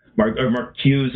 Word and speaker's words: Marcuse: Like, I found interesting the part between debates about the Vietnam War between Marcuse, the new left godlike figure, Marcuse, I don't know if I'm pronouncing his name correctly. That's right, yeah.